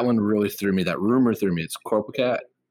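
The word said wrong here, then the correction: Coricopat: Is that how you pronounce it? I always pronounce it. – Corcopat. – Corcopat.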